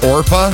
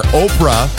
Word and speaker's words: Orpah: Did you know Oprah's given name was actually Orpah? O-R-P-A-H. Relatives kept mispronouncing it. They kept calling her Oprah. And I guess it just stuck after all those years.